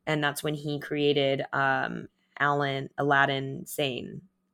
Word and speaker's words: Aladdin Sane: which it's spelt like Aladdin and the Magic Lamp, Aladdin, but it's like a lad insane.